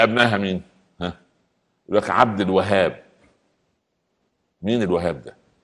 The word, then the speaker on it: الوهاب: الوهاب في شدة. عايز تكتبها باللغة الإنجليزية دبل اتش اتش اتش الوهاب هي كده. بالعربي الهاء عليها ايه؟ عليها شدة. ولما ننطقها لازم نقول عبد الوهاب مش عبد الوهاب.